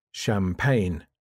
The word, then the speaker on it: champagne: The silent G. In general, the G isn't pronounced when it comes after an N. Foreign, cognac, champagne, sign, design, align. For example, let's celebrate with some champagne.